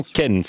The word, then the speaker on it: Kennes: C'est paru aux éditions Kennes. D'accord, c'est paru aux éditions Kennes. – Ça marche pas. – C'est mal. – La différence entre la prononciation à la française ou à la belge.